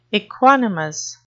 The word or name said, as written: equanimous